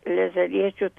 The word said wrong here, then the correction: Saleziečių: tą net neištariu knygą. Salės... Ir ten parašyta amžinos mišios. – Saleziečių žinios čia taip.